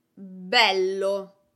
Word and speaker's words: bello: Another example is the word bello. Bello means beautiful. I am putting the accent on the first vowel, on the E sound. Bello, bello.